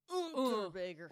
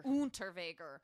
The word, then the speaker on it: Unterweger: It's Unterweger. – Unterweger.